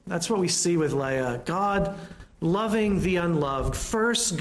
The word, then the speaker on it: Leah: Got to say that right in Hebrew. It's Leah, not Leah. Leah.